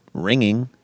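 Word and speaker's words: wringing: Is there any words with a silent W? – No. No, there aren't. Well, I mean, ringing. Like ringing your hands is. – Oh, yeah. – Oh, nice. – Yeah. – Like the W-R-I-N-G.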